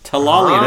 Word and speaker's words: Tellalian: Tellalian.